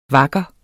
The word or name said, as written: vakker